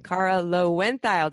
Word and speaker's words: Kara Loewentheil: It is episode time, and I just learned the proper pronunciation of Kara's name, Kara Loewentheil.